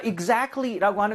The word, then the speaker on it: exactly: A의 강세를 세게 주고 해야 돼요. 그래서 T를 거의 탈락을 시켜야 됩니다. 그래서 발음을 할 때 exactly가 아니라 exactly. Exactly. 이런 식으로 중간에 A 쪽에다 강세를 많이 주셨으면 좋겠어요. 제가 두 번 다시 들려드릴게요.